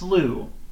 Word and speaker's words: Slew: It's pronounced Slu. It's S-L-L-E-W. Daryl W. sent me these. I believe it's his band. So again, that's Slu, S-L-E-W.